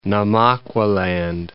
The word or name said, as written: Namaqualand